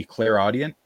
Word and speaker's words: clairaudient: And the second, I suppose, would be clairaudient, if I'm pronouncing that right. – That's right.